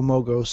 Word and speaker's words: Mogo: Now I'll just be waiting for my Lego Mogo set.